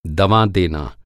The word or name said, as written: दवा